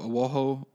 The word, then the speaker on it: Oahu: They all make the ship that they're on makes a port in Hawaii in, it's like O-A-H-U, Oahu, or I'm not sure how to pronounce it.